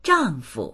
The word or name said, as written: zhang4fu